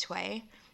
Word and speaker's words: TOAO: is Tway. And it's spelled T-O-A-O, which stands for the one and only. And like that was the only pronunciation that made sense to me was Tway instead of Twa.